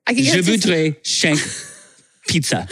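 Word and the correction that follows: cinq: It's not shank, sunk. It's sank.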